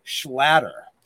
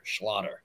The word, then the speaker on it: Schlatter: I always say it's like a, like it rhymes with otter, so it kind of sounds like a Jewish otter, Schlatter.